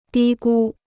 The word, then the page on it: 低估: dī gū